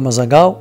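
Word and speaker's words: Mazagão: الاسم الآخر هو مازاغاو باللغة البرتغالية، بالنطق البرتغالي، لأن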